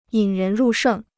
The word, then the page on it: 引人入胜: yǐn rén rù shèng